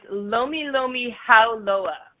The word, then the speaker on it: Lomi Lomi Hauloa: you, got it close enough. It's Lomi Lomi Hauloa. – Lomi Lomi Haloa.